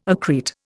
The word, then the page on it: accrete: Indian